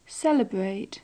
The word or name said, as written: celebrate